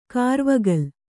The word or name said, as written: ಕರ್ವುಗೋಲ